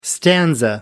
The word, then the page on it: stanza: American English